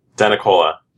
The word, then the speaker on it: DeNicola: DeNicola. – DeNicola. – It's okay. It got Americanized. That's probably the proper Italian pronunciation.